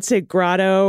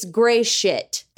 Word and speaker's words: Gratiot: Nope. No lie. It's gray shit. Hey, where do you live? I'm in Gray Shit, Michigan. – The county of gray shit.